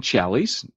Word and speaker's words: Challies: Well, hey, Tim, it's pronounced Tim Challies, and you got it. – He says it rhymes with valleys and rallies.